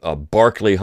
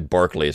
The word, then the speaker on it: Berkeley: And when they landed ashore, when they waded ashore and at a place called Barkley Hundred, now it's spelled Berkeley, but it's actually Barkley is how they would have said it, they had a Thanksgiving.